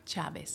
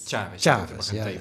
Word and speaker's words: Chávez: Een man, zeg je dat eigenlijk goed? Chavez? – Chavez. – Chavez, ja. maar de klemtoon wel op A, hè? Chavez.